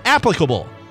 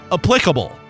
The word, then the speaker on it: applicable: It's applicable, not applicable.